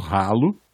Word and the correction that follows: Halo: tá certa a pronúncia? É ralo mesmo? – Você acha que é Halo? Não sei o que, que é esse efeito. em inglês? – Halo! Halo, é.